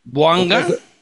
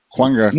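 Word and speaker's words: Bouanga: ¿Buanga? – Exactamente, es que no sabía pronunciar su apellido. – Juanga, no debes decir Juanga como John.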